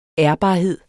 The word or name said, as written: ærbarhed